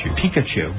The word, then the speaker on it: Pikachu: Pikachu? Am I pronouncing that right? – Pikachu.